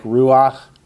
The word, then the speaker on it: ruach: This is just one of those unusual rules that we find in the first few chapters of the first book, that when you have a chait with a patak under it, it's pronounced ach, like ruach, noach, correct.